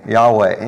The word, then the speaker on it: Yahweh: And so if you look at the word YHWH to try to pronounce God's name, well, kind of the best you can do without having any vowels to work with is just kind of say like Yahweh.